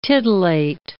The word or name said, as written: titillate